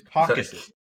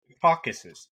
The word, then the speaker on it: Caucasus: It's Caucasus. Caucasus. How have you studied the world and like Caucasus? That's what you sound like. You sound like a bird. – That's a very hard first syllable. – It's like a caucus.